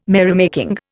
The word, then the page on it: merrymaking: Indian